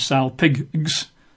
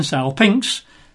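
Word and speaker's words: salpinx (σάλπιγξ): But the Greek word for trumpet is the Greek word salpinx, which you spell in English as S-A-L-P-I-G-X, as an x-ray, S-A-L-P-I-G-X, but you don't pronounce it as salpinx, it's pronounced with an I-N-X at the end, salpinx.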